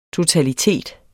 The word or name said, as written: totalitet